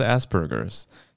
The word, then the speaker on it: Asperger's: 那每一个雅思的状态也不一样，对，所以它的英文是 Asperger's，因为呃 A S P E R G E R，可是因为声音听起来很像很像屁屁加汉堡，所以在欧美世界常常对常被拿来开玩笑，对，可是它是 Asperger's，所以发音要要注意要清楚，但。